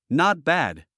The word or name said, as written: not bad